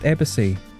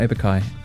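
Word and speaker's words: abaci: But apparently it's actually pronounced abacy, not abacai.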